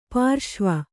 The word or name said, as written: ಪರಶಿವ